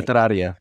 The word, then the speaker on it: Letraria: Letaria? – Oh, yeah. Okay. – Yeah. I never pronounce it properly. – Letraria.